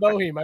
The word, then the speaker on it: Boeheim: Boeheim. – Yes, I said Bohem. I refuse to. – You pronounced it right that time.